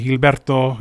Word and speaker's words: Gilberto: Or you can call me by my real name if you can pronounce it and roll your R's correctly. Gilberto, you know what I mean?